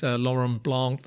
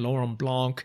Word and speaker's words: Laurent Blanc: If you're a football fan, you might remember France 98, Laurent Blanc, the French centre-half. I was pleased with the way I pronounced that. Let me say it again. Laurent Blanc,